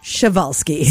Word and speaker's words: Przewalski: Now, this one was an interesting one because Chavalsky is spelt nothing like, it's pronounced nothing like how it's spelt. It's spelt P-R-Z-E-W-A-L-S-K-I, but pronounced Chavalsky.